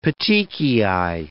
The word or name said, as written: petechiae